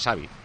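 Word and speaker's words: Xabi: Xavi es Xavi, en todo caso con SH, en todo caso, ¿no? Pero es Xavi, con S, normal, Xavi Alonso.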